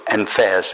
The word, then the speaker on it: Enfer: One was titled Rue Paradise and the other Rue Enfers. Is that how you say it? Enfer, E-N-F-E-R-S, Enfer.